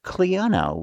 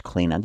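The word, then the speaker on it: Clíona: The one that's coming up next is entitled Cliona, which is or it can also be pronounced Cliona, depending on what part of Ireland I guess you're from.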